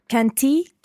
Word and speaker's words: canti: Canto, canti, canta, cantiamo, cantate, cantano. – Almost perfect. Well done. Just watch the stress of canto, canti, canta, the stress on the first syllable there.